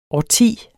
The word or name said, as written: årti